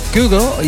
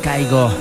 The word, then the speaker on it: Kygo: pronunciado en noruego, de donde es él, más o menos como Kygo, y en inglés Kygo.